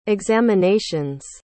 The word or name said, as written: examinations